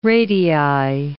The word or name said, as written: radii